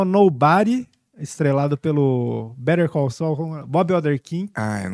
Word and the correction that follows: Bob Odenkirk: Bob Oderkin. eu esqueci, eu não sei pronunciar. Bob Odenkirk. É, Bob Odenkirk.